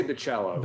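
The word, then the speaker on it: Bachalo: How do you pronounce Bacciolo? – I say Bacciolo. – Bacciolo. – Like it's like a soft A. – Okay, Bacciolo.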